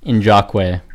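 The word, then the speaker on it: Ngakoue: The correct pronunciation is Yannick Njakwe. – What? How is there a J? – Yeah, like none of them were close. – I think can't. – Wait, can you just describe? Maybe I fucking slept in language power class, but how does an NG pronounce a J?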